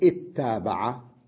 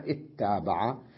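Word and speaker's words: اتّابع: ولذلك ممكن أن نقول اتابع، اتابع، لاحظ أجئنا بهمزة الوصل وكسرناها لما نطقنا بها في البداية، في البداية فقلنا اتابع، أو اتبع، اتبع.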